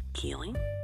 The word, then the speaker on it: Keeling: You guys be blessed, and if you're looking to find me, you can find me on Facebook, Sasha Keeling. The last name is spelled K-E-E-L-I-N-G.